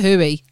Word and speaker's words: Huawei: He's gone balls deep on the hooey. – That's actually how you pronounce Huawei.